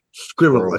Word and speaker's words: squirrel: Or as Americans say, squirrel attack. Squirrel attack. Squirrel, two syllables.